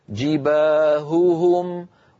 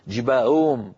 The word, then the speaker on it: جباههم: هوهم، جباههم، ولا نقول مثلا فتكوى بها جباههم، اسمعوني هكذا جباههم، وكأن الأصوات ضاعت وكأن الهاءات صارتا همزات مسهلات، جباهم، لا يصح هذا أبدا، بل لابد لنا من بيان صوت الهاء الأولى من صوت الهاء الثانية، وأن ننطق الهاء صافية ليست مشوبة بصوت آخر حلقي، هكذا.